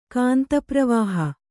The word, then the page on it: ಕಾಂತಪ್ರವಾಹ: kāntapravāha